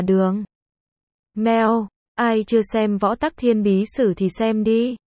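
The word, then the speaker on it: võ Tắc Thiên (武则天): Học võ Tắc Thiên, xưng đế, võ Tắc Thiên, cũng được đọc là Vũ Tắc Thiên, thường gọi võ hậu hoặc thiên hậu, là một phi tần ở hậu cung của đường Thái Tông Lý Thế Dân.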